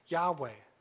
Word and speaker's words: Yahweh: Greek transcriptions also indicated that YHWH should be pronounced Yahweh. So there it is, based on the Greek, based on documents 1,800 years ago, they're saying it's Yahweh.